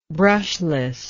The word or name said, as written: brushlessness